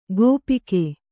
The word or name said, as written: ಗೋಪಿಕೆ